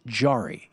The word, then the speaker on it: Jarry: Nicolas Jarry, he's a Chilean player. Nicolas Jarry, it's spelled J-A-R-R-Y.